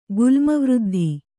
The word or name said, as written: ಗುಲ್ಮವೃದ್ಧಿ